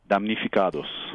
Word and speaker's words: Damnificados: Now, pronounce it for me again. – Damnificados.